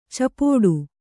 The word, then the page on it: ಚಪ್ಪಟ್ಟೆ: cappaṭṭe